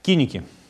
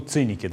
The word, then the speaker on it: Киники: Киники, ну, циники, да, их называют в вульгаризированном позднелатинском произношении.